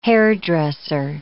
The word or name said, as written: hairdresser